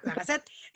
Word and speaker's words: La Gazette: Ella es Gabriela Cambero, mexicana, viviendo en París, editora en jefe de La Gazette. ¿Sí se pronuncia así, Gaby? – Sí, correcto. – Ok, La Gazette.